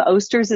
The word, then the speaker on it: Osters: So my last name, Osters, is very hard to pronounce. We talked about that earlier. Just people pronounce it oysters, osters, iesters, I've gotten before.